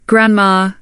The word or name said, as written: grandma